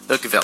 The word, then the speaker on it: Urquell: Now, how would you say that in German? – Urquell. Pilsner Urquell.